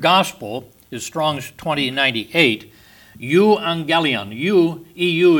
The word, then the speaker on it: Euangelion: And the GG is pronounced like NG. Euangelion.